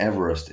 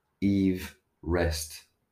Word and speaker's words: Everest: Mount Everest is pronounced Everest, not Everest, as it is named after George Everest.